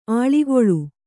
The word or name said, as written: ಆಳಿಗೊಳ್